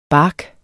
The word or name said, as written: bark